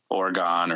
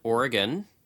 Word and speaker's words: Oregon: Oregon or Maine or Ohio. – All right, well, we'll correct you. Oregon. It's pronounced Oregon.